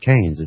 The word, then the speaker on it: Keynes: It's the way you pronounce it, Keynes.